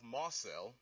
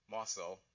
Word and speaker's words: Mosul: The Iraqi city of Mosul, spelled M-O-S-U-L, so I would have pronounced that Mosul, but no, it's Mosul, stands where Nineveh used to be.